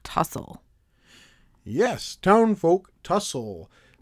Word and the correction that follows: Townsfolk: Did I say it wrong? Is it Townfolk? – Townsfolk okay.